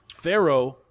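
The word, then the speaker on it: Pharoahe: Faro, by the way. – I was an 8th grade MVP on my basketball team. – Nice. – Faro is spelled strangely. It's P-H-A-R-O-A-H-E. And then second word, Munch, M-O-N-C-H.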